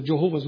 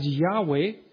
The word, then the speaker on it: Jehovah: After I prepared 3 Low German messages regarding the Jehovah's Witnesses, I am becoming hesitant to pronounce this name. But let me give it to you. Some pronounce it as Jehovah, others as Yahweh.